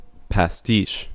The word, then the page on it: pastiche: American English